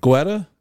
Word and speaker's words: Guetta: G-U-E-T-T-A? – Yeah. – Guetta? – Guetta. – Yeah. – Guetta? – That sounds about right.